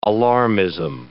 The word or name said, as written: alarmism